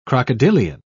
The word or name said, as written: crocodilian